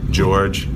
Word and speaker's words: Georges: The S is silent, like Des Moines, so George. Yeah, no, it's spelled in French, so it was George, but people just call me George.